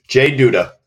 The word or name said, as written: Jay M. Duda